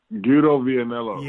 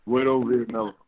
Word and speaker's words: Guido Vianello: His name's Guido Vianello. – Yes, that's it. That's it. – Guido Vianello.